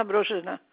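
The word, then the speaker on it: Brozyna: Yes, Christina Brozhina.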